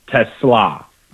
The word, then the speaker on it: Tesla: But, yeah, like you said, like, getting older now, like, everyone just thinks it's Tesla, which, I mean, I can't blame them. It's not like it's a very common last name that the pronunciation is super obvious. But, yeah, it is Tesla.